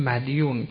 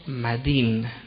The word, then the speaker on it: مدین: تلفظ عرفی عامیانه میگفتیم مدیون، که درستش چیه؟ مدین. مدیون غلطه، مدین یعنی کسی که دین بگردنش، دین.